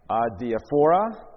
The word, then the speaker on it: adiaphora: The fancy word is adiaphora, adiaphora, adiaphora. It's pronounced different ways when I looked it up. A-D-I-A-P-H-O-R-A. Adiaphora, adiaphora, however you want to pronounce it.